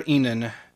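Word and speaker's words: Ihnen: And it's like the town of Enan, Ohio, despite the fact that it's spelled completely differently. – It's I-H-N-E-N.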